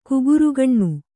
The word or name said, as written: ಕುಗುರುಗಣ್ಣು